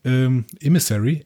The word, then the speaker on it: Emissary: Emissary. – Emissary. Genau, so wird es ausgesprochen.